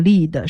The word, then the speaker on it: lead: 铅这个词呢，拼写和 lead leader 那个 lead 是一样的 L-E-A-D，但是它的发音是 lead，是铅的意思。